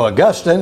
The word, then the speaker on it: Augustine: So Augustine is actually the better pronunciation of his name.